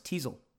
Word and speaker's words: TESOL: The main key to what Flora is doing is what Lambden calls templated solidity, which is spelled TESOL, pronounced Teasel.